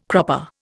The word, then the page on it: cropper: Indian